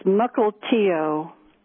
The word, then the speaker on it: Mukilteo: It's Mukilteo. – Mukilteo, that's how my call screener wrote it down, but I didn't know how to pronounce it.